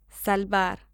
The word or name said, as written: salvar